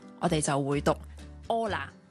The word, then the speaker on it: Hola: 咁 Hola 咧，佢系串 H O L A 嘅，但喺西班牙文咧 H 咧系永远都系 silent，即系唔发音嘅。系啦，咁所以咧，我哋见到人无论任何人啦，男女老幼，同佢打招呼 Hola，或者咧。熱情啲嘅可以講多幾次，Hola Hola Hola Hola，哦，原來就係咁。